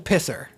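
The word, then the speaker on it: pissoir: The pisar. – Oh, I love it. Is that how you pronounce it? Pisar. I tried. – I think it's the pisser.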